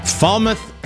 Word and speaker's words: Falmouth: Falmouth, Jamaica.